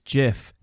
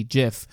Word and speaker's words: GIF: And yes, it is GIF, as in the peanut butter pronunciation. The guy who invented it says that it's J, like the soft G, GIF.